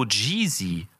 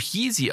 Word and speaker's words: Yeezy: Oder Elena, warum sagt sie zu Yeezy so Jeezy? Das wird doch Yeezy ausgesprochen.